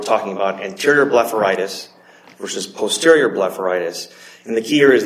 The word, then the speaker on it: posterior blepharitis: posterior blepharitis? Did I pronounce that correctly? – Yes, that's correct, okay.